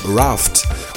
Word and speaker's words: Raft: Anschließend, mal wieder inspiriert von der Frankreich-Sendung von Ukase aktuell, Raft, französisch ausgesprochen, Yaka Danse.